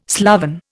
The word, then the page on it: sloven: Indian